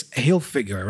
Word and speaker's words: Hilfiger: Because in English, it's Hilfiger, right? The stress syllable is the first one, Hilfiger, right?